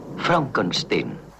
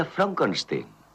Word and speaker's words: Frankenstein: Frankenstein. ¿Me toma el pelo? No, se pronuncia Frankenstein.